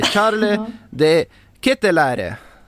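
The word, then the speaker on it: Charles De Ketelaere: Som heter, nu ska vi se om jag minns hur han själv sa att man skulle uttala det, Charles de Ketteläre tror jag det var. Och det var väl inte, det är väl inte ens så man säger egentligen, utan han har bara varit snäll här och gett, liksom, och sagt ett uttal nu att det här är lugnt, ni kan säga mitt namn så här. Men att uttalet egentligen är helt annorlunda.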